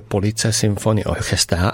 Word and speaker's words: Police Symphony Orchestra: Hlavně my si říkáme Police Symphony Orchestra, ale chápu, že plno lidí jako to chce vyslovovat Police jako policejní, ale bylo to trochu dělané s tím záměrem, ale my jsme z městečka Police nad Metují z východních Čech, takže proto se jmenujeme Police Symphony Orchestra.